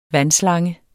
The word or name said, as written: vandslange